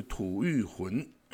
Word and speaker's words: 吐谷浑: 那玉呢，其实它的写法就是山谷的谷。但是它的读音是玉，那浑就是水很浑浊的浑，所以吐玉浑这个国家哈，它是从这个，它是一个非常古老的国家哈，它的建国时间呢是西元三百一十三年，然后它一直持续到唐朝六百六十三年的时候。